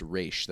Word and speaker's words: Ra's: And then all of a sudden, Talia goes, "Well, it's actually pronounced Ra's.